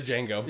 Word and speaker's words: Django: The D is silent. – Yep. – Yeah. – The Django.